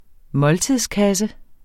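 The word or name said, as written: måltidskasse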